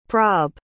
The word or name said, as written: prob